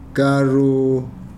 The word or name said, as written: karo